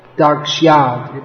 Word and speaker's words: dakshyad: There's a y after the sh, which, don't leave it out. Dakshyad.